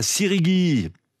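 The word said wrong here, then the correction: Siguiri: Sigiri, pardon, j'ai écorché le nom de votre ville, Sigiri.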